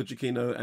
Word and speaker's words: Giacchino: And actually, if you look at Michael Giacchino's Twitter account, it actually says this is how it's pronounced. Very good score by Michael Giacchino, and it's very nicely lensed by Guy Godfrey.